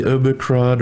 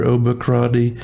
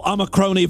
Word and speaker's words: Omicron: The Omicron or Omicroni thing variant. It's like a cold. Where did he get that? Like, no one has ever mispronounced it Omicroni. It ends with an N.